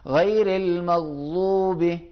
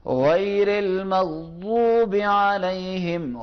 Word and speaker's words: غیر المغضوب: غیر المغضوب، اب میں پڑھوں گا غیر المغضوب، یہ ہر کوئی آدمی جو بھی سنے گا کہ یہ غلط تلفظ کر رہا ہے، غیر المغضوب یہ بھی غلط ہے.